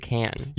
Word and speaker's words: Cannes: A little film festival I like to call Cannes. And it's not Cannes. – Oh. – It's not Cannes. There's a lot of different ways of pronouncing it, but it is Cannes.